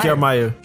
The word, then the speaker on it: Kiermaier: Kiermaier? – É, Kevin Kiermaier.